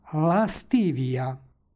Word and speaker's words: lastivija: stihu pročitali smo lastivija. Na početku stoji sonant, iza njega je suglasnik, između njih se dodaje nepostojano a radi lakšeg izgovora.